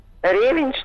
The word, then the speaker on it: ревень: Единственное, У меня на э, даче он не растет по требованиям российских словарей э, слово произносится ревень.